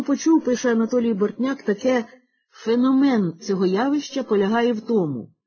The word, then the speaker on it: феномен: Втім, таке доводиться чути не раз, хоч за нормами вимови слово «феномен» треба наголошувати на другому складі.